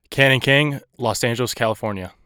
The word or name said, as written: Canon King